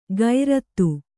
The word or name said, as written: ಗೈರತ್ತು